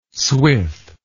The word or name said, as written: swith